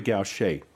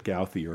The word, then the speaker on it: Gauthier: And we're going to listen to a song by a lady by the name of Mary Gaucher. And I'm really glad I looked up the pronunciation because I would have pronounced it Gauthier.